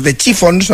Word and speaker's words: The Chiffons: Años antes había triunfado otra creación, He's So Fine, compuesta por Ronald Mac e interpretada por The Chiffons, no sé si lo he dicho bien.